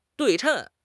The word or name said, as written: 对称